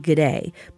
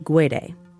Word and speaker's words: Guede: You might also hear it pronounced Guede, but Rudy himself pronounces it Guede.